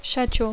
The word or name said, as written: schedule